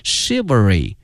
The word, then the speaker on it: chivalry: 今天我们学到了侠客的这个英文的翻译，它的发音是 chivalry， chivalry。同时同学们注意这个词的发音它跟一般的规律不一样这个 ch 没有念成这个 ch 这个音，而念成这个 sh 这个音，所以同学们要小心念成了 chivalry novels。